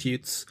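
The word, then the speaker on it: Hütz: I believe we pronounce it Hutz.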